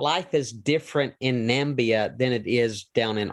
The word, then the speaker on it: Namibia: Now, again, most Americans are going to say the word Namibia, but actually the country is pronounced. Go ahead and help our listeners understand how to properly pronounce the country. – Namibia.